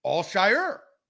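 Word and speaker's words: Al-Shaair: There's less emphasis on the ear at the end. It's Al Shire.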